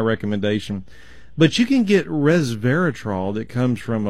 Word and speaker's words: resveratrol: R-E-S-V as in Victor, E-R-A-T-R-O-L, resveratrol. – Okay. – And the problem is the S is pronounced more like a Z, so you can get where I'm saying resveratrol.